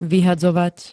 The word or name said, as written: vyhadzovať